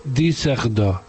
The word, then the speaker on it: Düsseldorf: Es que Düsseldorf lleva la Düsseldorf que se escribe, lleva dos puntos en la U. Y entonces se dice Düsseldorf.